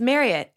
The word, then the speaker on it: Marriott: It's Marriott rhymes with chariot.